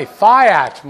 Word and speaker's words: fiat: So now we get to the final kind of money, fiat money. Fiat money. Fiat money, actually, it's actually pronounced fiat, but no one says it that way.